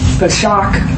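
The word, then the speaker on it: Pesach: And actually, this is very interesting because Passover, Peshach, it's P-E-S-A-K-H, is how it's pronounced. – Would you mind spelling that again? – It's actually, it's P-E-S-A-H.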